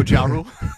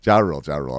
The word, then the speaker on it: Ja Rule: And one of his buddies in jail, who was there on a gun charge, was rapper J. Rule. – Oh, Ja Rule? – Ja Rule.